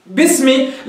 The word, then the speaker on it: باسمي: سمعت همزة؟ لا، باسمي، لأنه باء الجر مكسورة فكأنها خلاص هي التي ابتدئ بها، فصارت باء الجر مع كلمة اسم، فانتقلت من الباء إلى السين مباشرة من دون حاجة إلى ذكر الهمزة. باسمي، باسمي.